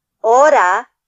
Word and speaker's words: hora: ¿Qué hora es? – Would you do that slowly so I can write it out phonetically? – Absolutely. ¿Qué? – Mm-hmm. – Hora, which is H-O-R-A, hora.